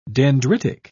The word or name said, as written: dendritic